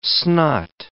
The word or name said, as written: snot